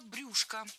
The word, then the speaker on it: брюшко: Дважды прозвучало брюшко. И это, в принципе, правильно. Сейчас такой вариант стал литературным. Однако, буквально год назад приоритетным было ударение на окончании брюшко. Но теперь за этим словом закреплена двойная норма. Так что можно говорить и брюшко, и брюшко.